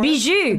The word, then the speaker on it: bijou: This is a bijou. I believe that's the French pronunciation for bijou.